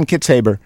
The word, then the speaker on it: Kitzhaber: Is that how you pronounce it? Or Kitzhaber? – Yeah. – Kitzhaber. Yeah. Okay, John Kitzhaber.